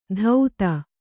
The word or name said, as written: ಧೌತ